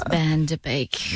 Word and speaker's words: Van Der Beek: No. Okay. No, I didn't get the name right. The correction was an incorrect name. – Wow. – Right person and then pronounce the name wrong. – James Van Der Beek.